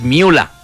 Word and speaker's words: Müller: Es Thomas Müller. No. Porque el ER... en alemán se pronuncia como una A.